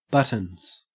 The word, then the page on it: buttons: Australian English